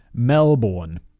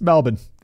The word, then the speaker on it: Melbourne: Like, I didn't live it down on Twitter forever, because I pronounced Melbourne, Melbourne, how it's spelled. And people were like, yo, fact boy, it's Melbourne.